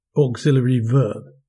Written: auxiliary verb

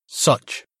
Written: such

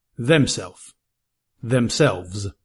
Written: themself

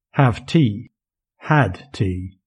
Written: have tea

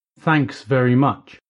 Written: thanks very much

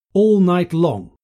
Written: all night long

